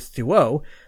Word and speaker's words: Thuot: Thuet is another pronunciation that I have to correct. His name is spelt T-H-U-O-T, so I went with Thuot, but nope, it's Thuot.